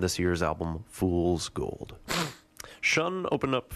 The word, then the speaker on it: Sion: From Kansas City, that was Sion, or actually Shun, as it's pronounced, double S-I-O-N.